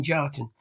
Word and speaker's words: Kjartan: And yet we've always pronounced it Jartan, because when my mother found the name in the book, she just knocked off the K, like a lot of other words with silent K, such as knickers. She knocked off the K and just read out what was left. And so I've always been Jartan.